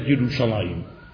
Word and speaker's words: Yerushalayim: Yerushalayim is spelled without a yud between the lamed and the mem. It is spelled Yerushalem, and it is read Yerushalayim.